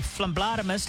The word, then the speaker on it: phlebotomist: Today we have Cheyenne, the phlebotomist. How do you say it? Phlebotomist? – Yes, that's right.